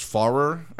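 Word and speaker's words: Pfarrer: The PF always gets me. So it's Farrer, I think. Chuck Farrer. – Yeah, the P is silent.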